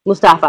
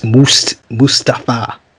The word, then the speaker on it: Mustafa: Mustafa Ali. – Excuse me. You got to say his name, Mike. It's Mustafa. Mustafa. It's Mustafa. Mustafa. Yes, he said say it right or don't say it at all.